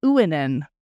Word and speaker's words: Uinen: And I think it's actually, so in the, in that notes on pronunciation, it says UI, as in Uinen, is the sound of English ruin. So I think it's supposed to be Uinen.